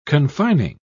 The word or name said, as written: confining